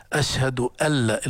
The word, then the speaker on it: أن لا: علم التجويد علم أدائي، علم لغوي، هي في الأذان أشهد أن لا، لكن هذه النون العرب لا ينطقونها، يدخلون النون الساكنة في اللام وبيسموها هذا إدغام بلا غنة. أشهد ألا، الخطأ هنا ليس خطأ شرعيا لكن خطأ لغوي، تشديد أن، يعني خطأ أن تقول أشهد أن لا خطأ، ولكن تقول أشهد أن، لكن هذه النون ندغمها فصارت أشهد أن لا إله الله وأشهد أن محمدا.